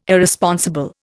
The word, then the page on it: Irresponsible: Indian